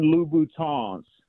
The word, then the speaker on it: Louboutin: And then most importantly, So the women that listen to the show don't start tagging you on YouTube, they are Christian Louboutins. You got to pronounce it with the French. – I'm Louboutin.